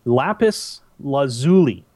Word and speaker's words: lapis lazuli: Trust me, it is lapis lazuli.